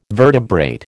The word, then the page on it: vertebrate: American English